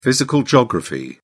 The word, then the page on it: physical geography: British English